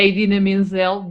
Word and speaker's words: Idina Menzel: É a Idina Menzel, diz da mesma forma que se escreve.